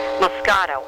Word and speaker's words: Moscato: E-E-N. Moscato, M-O-S-C-A-T-O.